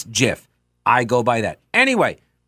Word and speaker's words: GIF: That's the way you pronounce that too, by the way. I'm not getting into that fight, but it is GIF.